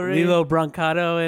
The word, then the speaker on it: Lilo Brancato: Lilo Brancato and whatever. Yes. Whatever. His name. Yeah. Am I saying his name right? – Yes. Yeah. Yes, you are.